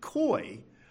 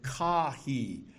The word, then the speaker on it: Caughey: He writes, "Growing up, we pronounced it Coy. But then I did my PhD at Trinity College in Dublin under Crawford Gribben, and the Irish pronounced it Caughey." And he transliterates that as C-A-W-H-E-E.